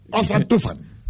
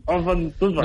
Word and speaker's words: Ozan Tufan: Ozan Tufan. – Muy bien. – Puedes decir Tufan. – Ozan Tufan.